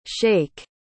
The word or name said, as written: shaik